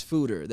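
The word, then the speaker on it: Foeder: I've seen that word around, and I'm like, how do I pronounce that? And it's Fooder, the Fooder.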